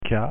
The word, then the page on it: k: French